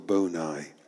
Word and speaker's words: boni: called the privatio boni. I'll spell that for you, since I can't write it. It's P-R-I-V-A-T-I-O, privatio. Boni is B-O-N-I.